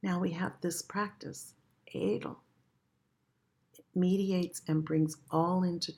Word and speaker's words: Adl: And the practice is, it's spelled A-D-L, A-D-L, and it's pronounced Adl, Adl.